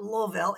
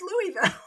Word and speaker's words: Louisville: So I feel like with Louisville, it's not Louisville, it's Louisville.